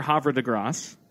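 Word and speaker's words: Havre de Grace: It was near Havre de Grasse. That's how it's pronounced.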